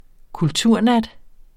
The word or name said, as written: kulturnat